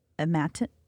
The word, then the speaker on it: imatinib: I scroll down to the bottom, and he takes a medication example, a 30-count supply of imatinib.